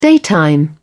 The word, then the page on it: daytime: British English